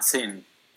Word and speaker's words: thin: Ah, thin. – Thin. Now I want you to put your hand to your throat and say it.